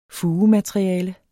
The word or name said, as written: fugemateriale